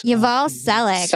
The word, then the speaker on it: Yuval Selick: We have Yaval Salik, and hopefully I said that correctly. – Yuval Selick, yes. – Yuval Selick. – Selick, yeah, of course. Like Tom Selleck.